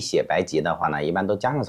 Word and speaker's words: 白及: 那么我们就把它按成及格的及，从中国药典上是没有草字头的。 – 好、嗯、好，那就白及。